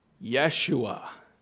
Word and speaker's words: Yeshua: In Hebrew, this son's name would have been pronounced Yeshua.